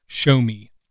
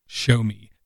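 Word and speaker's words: Xiaomi: And one of the things that they did was they teamed up with ShowMe, which is spelled nothing like the word sounds like, ShowMe, X-I-O-M-I-A or something,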